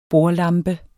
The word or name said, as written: bordlampe